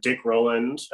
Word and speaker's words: Dick Rowland: And so the man was Dick Rowland. That's my understanding of the pronunciation of his name.